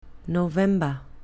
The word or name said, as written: november